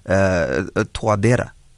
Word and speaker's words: Touadéra: The Central African Republic President Faustin Archange Touadéra, is that how you pronounce it, Touadéra? – Say it again, please. – Your president, your president's name, is it Touadéra? – Yes, that is his surname, and then that is his full name is Faustin Archange Touadéra.